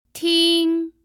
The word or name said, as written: ting1